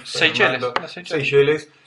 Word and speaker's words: Seychelles: Seychelles.